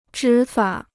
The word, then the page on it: 执法: zhí fǎ